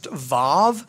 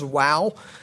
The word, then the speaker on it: vav: We used to think that that letter was pronounced vav, but more evidence has led us to believe that it's probably pronounced wow.